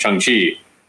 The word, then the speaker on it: Shang-Chi: Because I always said Shang-Chi because I just read it, but I was having a real hard time, like not remembering, but just like when I would say it, it would come out Shang-Chi, until I saw the movie and I hear him pronounce it Shang-Chi, and it's like, oh, there we go.